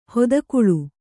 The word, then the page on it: ಹೊದಕುಳು: hodakuḷu